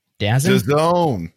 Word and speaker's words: DAZN: He worked formerly at, how do you say this company? DAZN? DAZN? – DAZN. – DAZN. He formerly worked at DAZN under John Skipper.